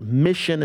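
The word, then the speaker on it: MICHN: Mission is how it's pronounced, but it's spelled M-I-C-H.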